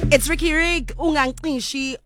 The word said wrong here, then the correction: ungazincishi: on. Did I say that right? – No, yeah, yeah, words. Yeah, yeah. You always, every time. – I said, what? – Ungazincishi.